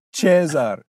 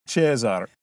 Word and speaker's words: Caesar: Auditores, ut audivistis, pronuntiat verba modo ecclesiastico, sicut nomen Caesar. – Non amice modo ecclesiastico. – Oh! Pro dolor. Cesar. Cesar.